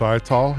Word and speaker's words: Ital: Vinyl is Vital rhymes with Ital here on Smile Jamaica.